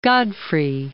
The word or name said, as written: Godfrey of Bouillon